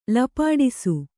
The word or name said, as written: ಲಪಾಡಿಸು